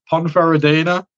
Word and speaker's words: Ponferradina: And it's Ibiza against Ponferradina. I hope I'm saying that right. – Oh, you are. Yeah, that's good pronunciation.